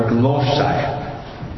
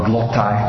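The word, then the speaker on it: glossa (γλῶσσα): You want the Greek word glossa, glossa. Or you could pronounce it glossa or glottai, depending on the dialect, or modern Greek, glossa, which means languages, as in the word glossary, languages.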